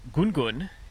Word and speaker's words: Ngungun: I'm at the base of the mountain that's spelt N-G-U-N-G-U-N. We often call it Gun Gun. You've heard Nunu before, but I've got it on good authority that potentially this third one, which is Gun Gun, is the correct pronunciation.